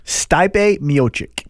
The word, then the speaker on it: Miocic: Stipe Miocic.